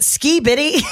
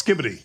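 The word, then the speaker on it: skibidi: Is it ski bitty? – Did you write that down? – I did. How do you say this, ski bitty? And Sam just goes, why don't you let me say it? – Apparently it's skibbity. – Skibbity. – It is skibbity, yes.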